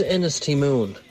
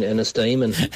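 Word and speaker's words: Ennistymon: A word my wife definitely can't pronounce is, the other night she was sitting on the couch and she said, where's Ennis T. Moon? And I said, you mean Ennis Damon? – Ennis T.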